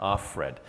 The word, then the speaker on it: Offred: Okay, Offred. – Yeah. Offred.